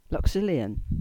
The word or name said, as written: Luxulyan